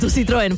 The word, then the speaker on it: Citroën: Es que hay que pronunciar bien el francés. Citroën fue fabricado entre el 2010 y el 2018.